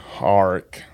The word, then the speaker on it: Auric: Auric. Auric. A-U-R-I-C.